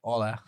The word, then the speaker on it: hola: It's silent. It's like in Spanish. I was treated in the DR, so H is silent. Sorry, we don't say hola. We say hola.